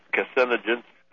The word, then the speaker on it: Kucinich: Like we're watching some kind of setup, a manipulation, disinformation campaign, and at the very same time, congressmen like Dennis Kucinich, I can't say his name, from Ohio. – Yeah, Kucinich.